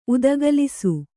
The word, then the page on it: ಉದುಗಲಿಸು: udugalisu